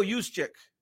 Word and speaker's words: Juszczyk: Juszczyk. – Like, yeah. – There's no way you know his name. – How you say it? – Kyle Juszczyk.